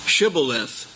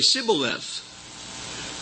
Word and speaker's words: Shibboleth: Now here's an example from Judges 12:6, where they had a problem with saying exactly what they meant because they couldn't quite pronounce the word Shibboleth, They would say Sibboleth. They didn't have the H sound.